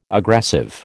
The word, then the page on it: aggressive: American English